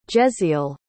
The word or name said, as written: jezziel